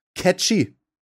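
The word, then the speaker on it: Cait Sith: Spricht man den so aus? – Ja, der wird catchy ausgeführt. – Nein, da steht tatsächlich Sprache catchy.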